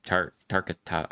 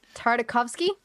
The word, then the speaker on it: Tartakovsky: That's actually an animation trait of Gendy Tarkata. I'm gonna butcher his name. He's got a really elaborate name. – Tartakovsky.